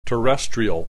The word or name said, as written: terrestrial